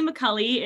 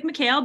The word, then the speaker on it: Micale: Like you accurately pronounced my last name, I'm Natalie Micale. It looks like Micale, but it's pronounced like Macaulay Culkin.